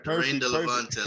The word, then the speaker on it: Daraine Delevante: Daraine Delevante.